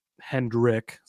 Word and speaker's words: Hendrick: I mean, you go through here and it's It's Hendrix. It's 2311. Hendrick. Sorry, it's not Hendrix. Hendrick.